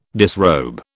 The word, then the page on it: disrobe: British English